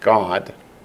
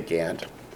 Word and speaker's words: Gad: And of Gad, of course, you realize in Hebrew it would be pronounced God, but that sounds too much like our English word God, so we say Gad.